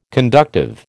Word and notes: conductive: American English

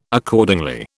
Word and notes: Accordingly: British English

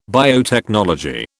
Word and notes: biotechnology: British English

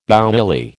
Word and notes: bouilli: American English

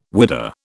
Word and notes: whidah: British English

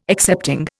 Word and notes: excepting: Indian

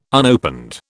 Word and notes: unopened: British English

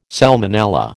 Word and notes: salmonella: American English

